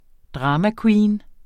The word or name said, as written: dramaqueen